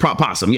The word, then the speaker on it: opossum: Okay, North America is opossum. All right, cool. Yeah, they're both pronounced possum. Yes, I'll still say possum.